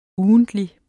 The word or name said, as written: UGENTLIG